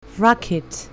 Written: rocket

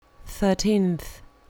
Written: thirteenth

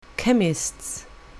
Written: chemist's

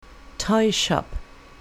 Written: toy shop

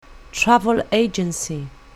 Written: travel agency

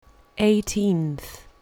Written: eighteenth

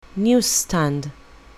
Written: news-stand

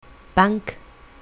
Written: bank

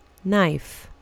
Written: knife

